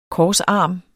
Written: korsarm

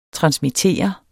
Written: transmitter

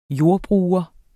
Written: JORDBRUGERENS

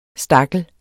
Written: STAKLEN

